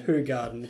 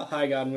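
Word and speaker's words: Hoegaarden: Anyway, this is actually pronounced... – pronounced Ho Garden. It's not how High Garden.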